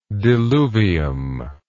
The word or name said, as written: diluvium